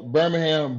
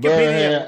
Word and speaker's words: Birmingham: I don't know if I'm pronouncing that correctly. Is it Birmingham? Should I? – Yeah, you hit it right. Birmingham. – But your Wikipedia, Birmingham, Alabama.